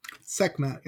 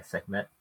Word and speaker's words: Sekhmet: And I'm probably going to butcher her name, Sekhmet. – Sekhmet, yeah. – I'm going to say, yeah, Sekhmet.